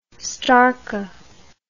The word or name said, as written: starka